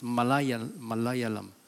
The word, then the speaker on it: Malayalam: I know I sound like Indian, but it is how it's being pronounced. Malayalam.